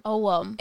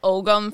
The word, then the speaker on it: Ogham: Ogham. It's okay. It's spelled O G ham. – Yeah. So it was Ogum for the longest time, and I'm like, I don't know which one it is.